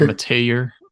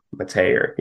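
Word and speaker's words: Mettauer: Now, their left guard is the transfer from Cal, McCade Metallier. My mouth just does not want to form that name. Like, I know how it's pronounced. – Like Metayer? – Metayer, yeah.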